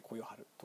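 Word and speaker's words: 呼世晴: 呼ぶ」「世界」「晴れる」と書いて「こよはる」と読まれるそうなんですけれども、えー、という方が作者だそうです。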